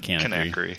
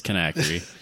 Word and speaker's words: Kanakry: It's not Kanakry. It's Kanakry. There's no E in there, but no.